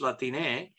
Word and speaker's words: Latine: But if you put an E there, it's Latine.